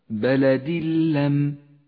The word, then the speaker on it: بلد لم: ندغم التنوين باللام فيسقط التنوين من اللفظ نهائيا ولذلك هو رمادي اللون وأما اللام فتشدد ويصير النطق هكذا بلد اللم